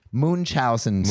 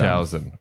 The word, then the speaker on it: Munchausen: Munchausen syndrome. – Munchausen.